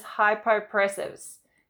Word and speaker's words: hypopressives: So the word is hypopressives, and hypopressives translates like this.